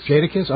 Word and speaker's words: Jadakiss: such as rappers Jadakiss. And did I say it right? – Jadakiss.